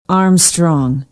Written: armstrong

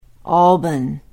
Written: alban

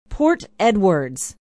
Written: Port Edwards

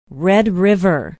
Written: red river

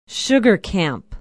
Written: sugar camp

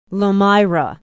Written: lomira

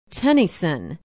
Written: tennyson

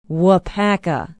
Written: waupaca

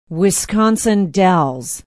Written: Wisconsin Dells

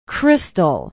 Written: Crystal

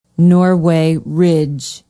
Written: norway ridge

